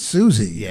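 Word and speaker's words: Siouxsie: Then there was one by Suxie and the Banshees. – I think it's Susie and the Banshees, actually. The X is silent.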